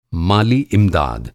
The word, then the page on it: माली इमदाद: مالی امداد